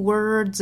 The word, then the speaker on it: words: When I find myself in times of trouble, Mother Mary, mother r r 单词尾部含有字母 r，美音这里卷舌成 mother， Mother Mary comes to me Speaking words of wisdom. Words 同样我们说有个卷舌呢，还要注意 words 尾部是一个有滋有味滋的发音，跟后面连读就是 words of words of wisdom words of wisdom。